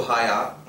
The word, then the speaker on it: Hagia: Hned naproti té modré mešitě je mešita Hagia Sophia, známá strašně, píše se to Haya, oni Turci ho nevyslovujou.